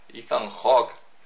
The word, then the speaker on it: Ethan Hawke: junto a Ethan Hawke. – ¡Oh, la, la! – Ethan Hawke.